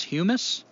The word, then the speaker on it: humus: It's not hummus. Humus? Like humus. Earth? – Yeah.